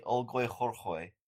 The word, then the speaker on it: Olgoy Khorkhoy: Its name in Mongolian, Olgoy Khorkhoy. – No. You didn't say anything just then. Try again. – I said Olgoy Khorkhoy. – Is that really how it's pronounced? – It's definitely Olgoy Khorkhoy.